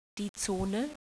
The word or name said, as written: zone